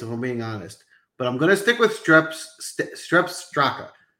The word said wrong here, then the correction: Sepp Straka: Jeez, I can't say his name. Sepp Straka.